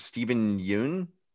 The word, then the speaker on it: Steven Yeun: Steven Yeun.